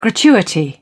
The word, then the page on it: gratuity: British English